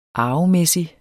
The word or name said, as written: arvemæssig